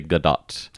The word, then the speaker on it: Gadot: So just to correct, it's actually Gadot.